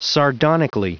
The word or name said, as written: sardonically